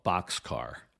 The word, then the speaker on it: Bockscar: That B-29 had the nickname of Boxcar. That's spelled B-O-C-K-S-C-A-R. It was a play on words, like a railroad boxcar, but spelled differently because it was first commanded by Captain Frederick Bock.